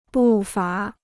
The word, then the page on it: 步伐: bù fá